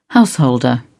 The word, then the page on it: householder: British English